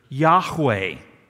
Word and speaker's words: Yahweh: and they figured out that the most likely pronunciation of God's name is the word Yahweh.